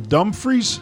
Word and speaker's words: Dumfries: And we're also going to go with one of his players here, Lewis Crombie, who is a sophomore from Dumfries, Scotland. Did I say that right? – Yep, that was pretty good.